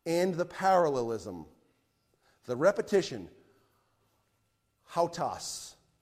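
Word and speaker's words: hautas (οὕτως): It looks like an O-U-T with a W-S. I know it looks like something different than hautas, but that's how you say it in Greek. That repetition of hautas, the word hautas means after this manner or on this wise or so.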